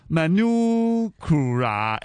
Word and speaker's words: Manukura: Manukura M A N U K U R A 咧，我哋嘅发音啦吓。